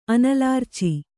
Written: ಅನಲಾರ್ಚಿ

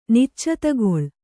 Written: ನಿಚ್ಚತಗೊಳ್

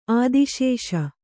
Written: ಆದಿಶೇಷ

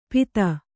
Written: ಪೀತ

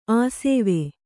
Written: ಆಶ್ವ

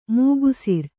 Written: ಮೇಗುಸಿರ್

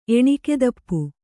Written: ಎಣಿಕೆದಪ್ಪು

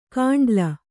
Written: ಕಾಂಡ್ಲ

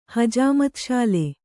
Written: ಹಜಾಮತ್‍ಶಾಲೆ

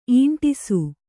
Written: ಈಂಟಿಸು

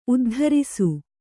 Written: ಉದ್ಧಾರಿಸು

